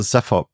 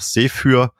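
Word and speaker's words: Zephyr: Ich persönlich sage Cepher Project. Also Cepher ist die vermutlich auch gebräuchlichste Aussprache, auch im englischsprachigen Raum. Im Deutschen wäre aber auch Sephyr, glaube ich, in Ordnung.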